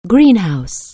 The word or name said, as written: greenhouse